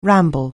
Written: ramble

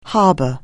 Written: harbour

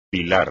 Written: pilar

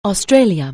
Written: Australia

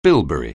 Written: bilberry

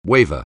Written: waver

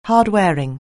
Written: hardwearing

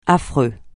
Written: affreux

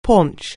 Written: paunch

